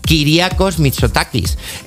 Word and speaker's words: Kyriakos Mitsotakis: Bueno, este proyecto ha sido aprobado ayer jueves, gracias a la conservadora Nueva Democracia, el partido con mayoría absoluta del primer ministro Kyriakos Mitsotakis.